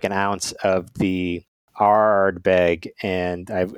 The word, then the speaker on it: Ardbeg: If I was going to pronounce it, I would say Ardbeg, like a pirate.